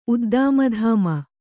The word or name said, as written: ಉದ್ದಾಮಧಾಮ